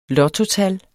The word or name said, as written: lottotal